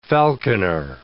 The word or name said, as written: falconer